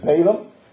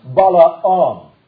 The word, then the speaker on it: Balaam: Because you have there those who hold to the doctrine, and we always pronounce his name as Balaam, but it actually is pronounced Balaam.